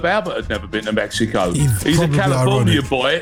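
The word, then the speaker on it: México: When you get there, don't pronounce the X the way that we do. Just say Mexico.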